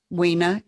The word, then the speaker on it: Weena: Weena is actually pronounced Weena. – Weena, which is beautiful, but you southerned it up.